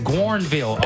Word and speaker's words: Guerneville: How you say that? Gorville? But there's an N. Guerneville.